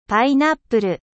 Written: ananas

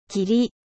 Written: 霧

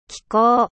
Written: climat

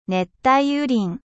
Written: foret tropical

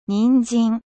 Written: carotte

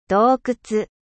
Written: grotte

